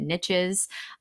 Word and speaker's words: niche: And people, I'm sure you've heard it pronounced different ways. You know, there's even the quote, the riches are in the niches, but for all intents and purposes, we're pronouncing it niche.